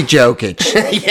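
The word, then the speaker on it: Jokić: It's going to be Jokic. – Yeah, if you don't want a J pronounced, don't bring a J.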